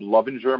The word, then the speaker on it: Lovinger: Look up Bob Lovinger.